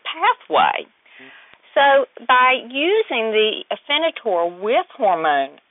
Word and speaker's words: Afinitor: Well, I want to ask you about this, if I could pronounce it right, Afinitor, is that right? – That is correct.